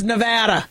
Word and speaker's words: Nevada: They even mispronounce the state I live in. I live in Nevada, and people call it Nevada. You know, like they're trying to make a more bougie sounding. It's Nevada, right?